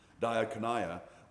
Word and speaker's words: diakonia: The word for ministry that's used here in 2 Corinthians is diakonia. There you go. I looked it up and listened to several different pronunciations. Diakonia.